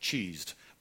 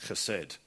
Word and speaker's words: chesed: well it technically looks like, looks like cheesed but i'm told it's pronounced chesed and it's translated in quite a number of different ways that I want to look at.